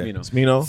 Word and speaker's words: Smino: 49 is I don't know if you pronounce it. Smino. Is it Smino? – Yeah. – Smino.